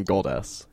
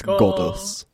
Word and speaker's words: GODUS: When you say it, it sounds like you're saying goddess. It's GODUS.